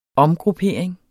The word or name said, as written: omgruppering